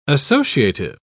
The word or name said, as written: associative